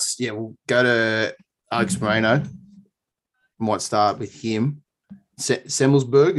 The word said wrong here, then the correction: Semelsberger: is that how you say his name? Matthew Semelsberg? – Yeah, Semelsberger.